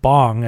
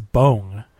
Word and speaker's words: Bong: They said it's not pronounced like Bong, as everyone says it in America, that it's Bong.